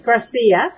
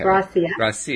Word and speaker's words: Gracia: Gracia. – Gracia. Okay. – Gracia.